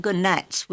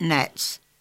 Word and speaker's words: gnats: They're called gnats with a G. – Well, okay. – And you only pronounce it gnats.